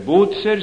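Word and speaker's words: Bucer: Bozer, som vi altså må uttale ikke med den affekterte latinske uttale som dere lærer til forberedende prøve, hvor man altså vil endelig at vi skal si Kesar og Kikero, men det skal uttales slik som den humanistiske tradisjonen har vært, og da blir det Bozer.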